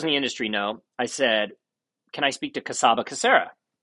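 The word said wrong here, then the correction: Csaba Csere: you're pronouncing his name wrong. And it turns out his name is Chubachetta, still to this day, a really good friend of mine.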